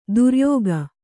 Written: ಧೌರೇಯಕ

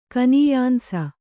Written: ಕನೀಯಾಂಸ

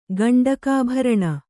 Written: ಗಂಡಕಾಭರಣ

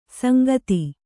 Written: ಸಾಂಗತೆ

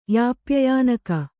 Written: ಯಾಪ್ಯಯಾನಕ

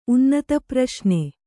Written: ಉನ್ನತಪ್ರಶ್ನೆ

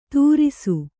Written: ತುರಿಸು

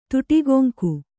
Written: ತುಟಿಗೊಂಕು

